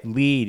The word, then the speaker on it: Lied: Dude, Elfin lied, right? – Lead, yeah, it's pronounced lead, but yes.